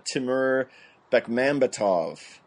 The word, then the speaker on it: Timur Bekmambetov: The crazy Russian dude, I'm going to say this wrong, Timur Bekmambetov.